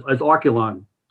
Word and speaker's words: Archelon: It was spelled A-R-C-H-E-L-O-N. So they said, Grandpa, you have to spell it out so we know how to pronounce it. – Ah. – They gave me the idea to have the, to say how it's pronounced as Arculon, so that was one of their ideas.